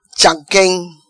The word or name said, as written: Chamquem